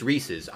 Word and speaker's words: Reese's: Is it Reese's or is it Reese's? How do you say that when you talk about that candy? Reese's. – Correct, Reese's. yeah, correctly, it's Reese's.